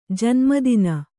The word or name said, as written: ಜನ್ಮದಿನ